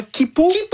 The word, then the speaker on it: Quipu: Et si vous pensiez que c'était déjà vertigineux d'imaginer l'Anéakea, attendez qu'on vous parle de comment Kipu. Ça s'écrit Q-U-I-P-U.